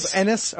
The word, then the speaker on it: Ennis: I hope Ennis. Okay, yeah. Everything that he has written you said it right.